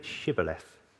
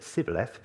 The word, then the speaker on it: Shibboleth: If he replied, "No," they said, "All right, say Shibboleth." If he said Sibboleth because he could not pronounce the word correctly, they seized him and killed him at the fords of the Jordan.